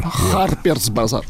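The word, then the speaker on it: Harper's Bazaar: Даже не хелп, как там, херперс базар. Харперс базар.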